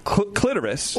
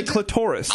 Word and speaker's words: clitoris: I noticed you went for the optional pronunciation clitoris. – Well, yeah, because I say clitoris. – I say both, actually.